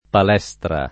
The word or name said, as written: palestra